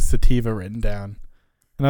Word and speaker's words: sativa: Yes, sativa.